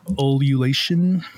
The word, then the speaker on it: ululation: Pronounce this word. – Ululation. – Ululation, yeah.